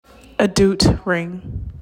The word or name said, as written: Adout